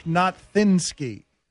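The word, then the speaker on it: Nowitzki: I don't think my father pronounced that correctly. It's Dirk Notthinsky.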